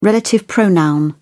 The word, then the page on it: relative pronoun: British English